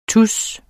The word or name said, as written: TUSSEN